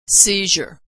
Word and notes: seizure: zh

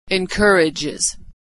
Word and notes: encourages: Iz

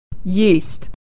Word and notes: yeast: /y/

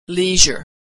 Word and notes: leisure: zh

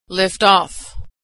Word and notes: liftoff: verb

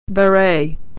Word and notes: beret: ey

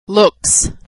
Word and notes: looks: s

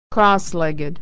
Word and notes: crosslegged: ed

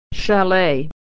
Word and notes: chalet: ey

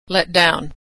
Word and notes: letdown: verb

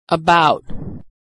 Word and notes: about: aw

